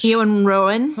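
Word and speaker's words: Iwan Rheon: how do you pronounce the name of the guy who plays Ramsay? It's very Welsh. – Ewan Rowan.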